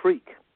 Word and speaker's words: Freke: So the first thing I need to tell you, Jenna, it's pronounced Freke. My second it's an old English name. It's spelled F-R-E-K-E, but it's actually pronounced Freke, which means you've got a good sense of humor.